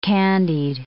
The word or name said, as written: candied